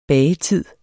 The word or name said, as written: bagetid